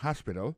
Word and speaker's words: hospital: L'hôpital, c'est comme ça, on dit ça en anglais, hospital. Alors, accent tombe sur premier syllabe, il y a trois syllabes. She likes to visit hospital. Hospital l'a écrit H-O-S-P-I-T-A-L.